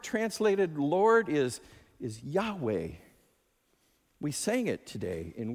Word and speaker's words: Yahweh: What's beautiful about how we pronounce that now is you can almost breathe in on the first syllable and breathe out on the second. Yahweh.